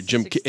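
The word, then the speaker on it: Kiick: What about Jim Kick? – Well, that was in 74, I believe. – Is that how you say his name? – Jim Kick. – There you go.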